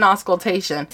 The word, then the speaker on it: auscultation: It's intermittent auscultation.